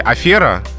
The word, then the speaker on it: афера: А вот в слове «афера» как раз-таки произносится «э», а не «о». Что тоже связано с происхождением из того же французского языка.